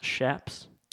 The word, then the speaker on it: chaps: It's actually pronounced shaps. – Oh, is it? – Yeah, like S-H due to being a Spanish word.